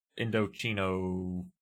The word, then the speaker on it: Indochino: Oh, and Fringe, if you want to look at this Indochino... Did I say it wrong? – You said it right.